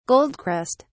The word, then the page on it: goldcrest: British English